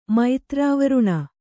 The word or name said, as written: ಮೈತ್ರಾವರುಣ